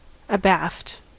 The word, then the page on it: abaft: American English